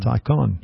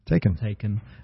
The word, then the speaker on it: Taycan: How do you say that? Is it Taycan? – Taycan. – Yeah. – Taken.